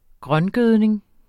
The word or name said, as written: grøngødning